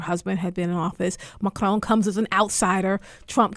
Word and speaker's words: Macron: I mean, I look at Le Pen and I look at Macron, and I'm probably not pronouncing it right. Macron, I'm trying Macron.